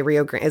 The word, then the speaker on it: Rio Grande: And she was, like, basically, like, white people say Grand, Mexicans like, say Grande.